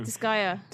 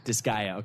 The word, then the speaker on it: Disgaea: Disgaea. – Disgaea, okay.